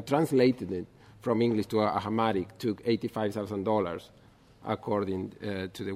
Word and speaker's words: Amharic: Amharic version, or Amharic, is a language? Amharic.